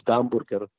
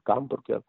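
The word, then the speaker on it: gamburger (гамбургер): We call it, we pronounced it gamburger. The H is often a G in Russian, gamburger.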